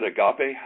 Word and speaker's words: agape: I pronounce it agape. I know that there's different ways to pronounce this word. Agape.